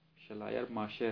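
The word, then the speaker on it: Schleiermacher: میں نے مختلف کتابوں میں رجوع کیا تو وہ آر کے ساتھ تھا آخر میں، تو شلائر معاشر اس کا تلفظ بنے گا اور فریڈیک شلائر معاشر.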